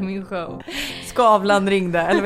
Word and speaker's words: Skavlan: Skavlan.